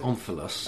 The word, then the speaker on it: omphalos: I pronounce it omphalos.